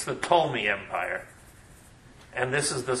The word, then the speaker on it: Ptolemy: And there is the Ptolemy empire, spelled with a P, the Ptolemy empire, based in Egypt.